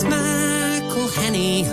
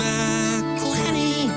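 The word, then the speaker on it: McElhenney: Pronouncing all those N's and E's and H's can perplex them. So here's a little birthday gift from all your mates in Wrexham. It's McElhenny, McElhenny. What ways to massacre and mispronounce it, there are many. – McElhenny. – It's McElhenny. – McElhenny. – McElhenny. – McElhenny. – If there was doubt, now there won't be any.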